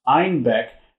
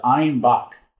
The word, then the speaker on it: Einbeck: Due to their Bavarian accent, citizens of Munich pronounced Einbeck as Einbach, a billy goat, and thus the beer became known as a Bock.